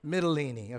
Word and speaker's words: Mytilene: I'm not just trying to sound Italian. That's how it's pronounced, okay? Mytilene, okay?